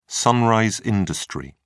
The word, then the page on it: sunrise industry: British English